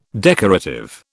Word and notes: decorative: British English

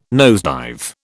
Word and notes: nosedive: British English

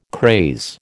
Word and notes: craze: American English

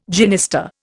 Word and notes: genista: Indian

